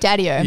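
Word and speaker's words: Dadio: Is it Daddy-O or Daddy-O? – Daddy-O. No, it's definitely Daddy-O.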